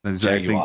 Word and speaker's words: Jaguar: I like to go with the proper pronunciation of Jaguar. – Jaguar.